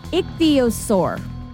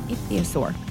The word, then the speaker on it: Ichthyosaur: I'm going to try to not butcher this. – Uh-oh. – Ichthyosaur. – Nailed it. – Ichthyosaur.